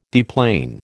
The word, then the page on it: deplane: American English